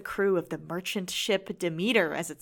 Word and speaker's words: Demeter: I thought it was Demeter, but I also... – It is Demeter.